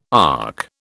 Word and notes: ark: British English

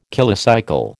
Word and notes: kilocycle: American English

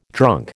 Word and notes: drunk: American English

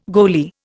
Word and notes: goalie: Indian

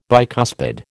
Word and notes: bicuspid: American English

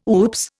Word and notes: oops: Indian

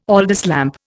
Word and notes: Aldis lamp: Indian

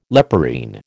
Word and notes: leporine: American English

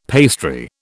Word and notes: pastry: British English

